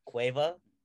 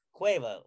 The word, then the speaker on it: Cueva: And I go, it's Cueva. And I go, and I help him, he goes, what? And I go, Cueva, like Cuervo. Think about like Jose Cuervo.